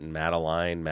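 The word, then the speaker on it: Matalin: It's not spelled Madeline. It's M-A-T-A-L-I-N, but Madeline. Okay. I don't know. Mary Madeline. – That's the first name? – That's the second name. Mary Madeline.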